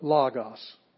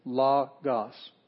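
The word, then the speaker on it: logos (λόγος): We have the accent mark above it, logos. So that's the one we hit. But two vowels, omicron. The rest of them are consonants. So it's two syllables, logos.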